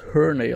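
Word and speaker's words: Hurney: Marty Herney, Har Harney, mhm. ne Herney, weil er wird mit U geschrieben, da würde ich eher das Herney aussprechen. Ähm, eigentlich war er ja, fand ich eigentlich ganz gut ein Rebuild gemacht bis jetzt.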